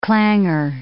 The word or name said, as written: clangor